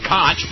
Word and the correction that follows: Koch: because it's KOCH, but I am politely informed that it is pronounced Coke, which I should have known because that great English jurist whose name was spelled C-O-O-K-E was also pronounced Coke.